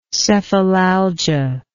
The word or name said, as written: cephalalgic